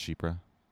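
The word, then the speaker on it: chypre: Yeah, so this is what your chiper would smell like. – No, it wouldn't. – Yeah. – No, citrus. No, it would not. They don't all have citrus in them. By the way, it's pronounced Shipra.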